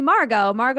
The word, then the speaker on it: Margo: And so like everyone called me Margo, that's my name, Margo. And then it was like, Margo. I was like, oh my God, this is terrible. – Why the emphasis on the second? – I don't know.